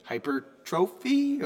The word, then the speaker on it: hypertrophy: So we are going to spend our entire episode talking about that magic buzzword, hypertrophy. Ah, there it is. – This is on the second syllable.